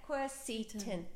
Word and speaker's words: quercetin: Q-U-E-R-C-I-T-N. – Beautiful. – Quercetin. – Okay. Quercetin. – Am I saying it right? Q-U-E-R. Yeah. Quercetin. C-E-T-I-N.